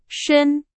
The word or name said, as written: 深